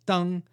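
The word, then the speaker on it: Deng (灯): If they wrote the word for lanterns, also pronounced Deng, they'd be punished for breaking the taboo.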